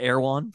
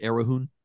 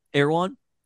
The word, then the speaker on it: Erewhon: you probably pronounce it Erahoon. No. – Would I overpronounce it or underpronounce it? – Just in Unclear. – no, just incorrect. Also, you know what, Erewhon, also, like, people talk about how expensive the smoothies are.